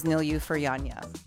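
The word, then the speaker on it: Nilüfer Yanya: And before that, we listened to some, actually, Dean, you were the one who helped me pronounce her name correctly. It was Nilyu Furyanya.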